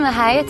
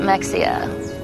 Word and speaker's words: Mexia: Welcome to Mejia, Texas, my hometown. Most people pronounce it Mexia, and it's not. It's Mejia.